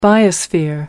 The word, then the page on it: biosphere: American English